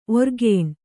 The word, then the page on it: ಓರೆಗಣ್ಣು: ōregaṇṇu